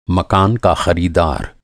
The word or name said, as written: ख़रीदार